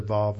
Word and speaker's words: Vav: But when you go to Israel, they all pronounce the Vav like a V. They don't pronounce it like a W.